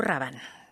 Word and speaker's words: Rabanne: Y Beth Parga, sí se pronuncia Rabanne, ¿verdad? – Paco Rabanne. – Ahí está. – Ya se confirmó.